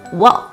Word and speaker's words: walk: 工作读作 work，而走路读作 walk，区别就在于 work 的卷舌音。